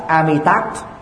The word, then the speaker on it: Amitabha: Tiếng Sanskrit là Amitabha, chữ Phật là Bút. Nếu chúng ta gọi đủ là Namo Amitabha Bút, Namo Amitabha Bút, người Việt Nam có thể phát âm chuẩn đến 90+% so với Trung Quốc.